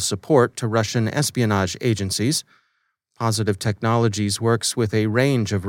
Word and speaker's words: Positive Technologies: It's simply pronounced Positive Technologies, transliterated into Cyrillic, and then transliterated back into the Roman alphabet.